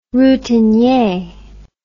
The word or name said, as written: routinier